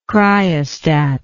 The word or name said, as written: cryostatic